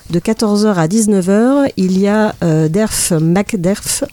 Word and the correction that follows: Backderf: non, Backderf, pardon, j'ai mal prononcé son nom, euh, qui sera en dédicace au Bidibule.